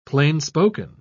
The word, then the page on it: plainspoken: British English